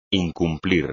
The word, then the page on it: incumplir: espagnol